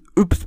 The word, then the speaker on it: Yps: Okay, ihr habt euch letzte Folge gefragt, wie man Jups Banane ausspricht. Und man spricht es Yps Banane.